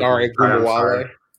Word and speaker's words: Ogunbowale: They also have Dare Ogun. I can't pronounce his last name. I'm not Dare Ogunbowale.